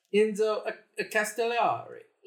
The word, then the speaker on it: Enzo Castellari: Enzo Castellari.